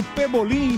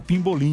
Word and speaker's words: Pebolim: Eu descobri que eu sempre falei errado. – Falou errado, você fala Pimbolim.